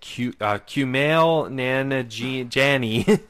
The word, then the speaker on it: Kumail Nanjiani: The guy, you know, main guy in it, Kumail Nanjiani. – That was a really good job pronouncing it.